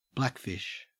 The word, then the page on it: blackfish: Australian English